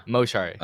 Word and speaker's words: Moshari: Moshari.